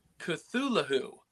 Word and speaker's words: Cthulhu: Or as I've been told by George Norrie, who hosts Coast to Coast AM, that is actually pronounced Cthulhu. – Oh, you actually pronounce it with the H. Interesting. Yeah. I thought that was a silent H.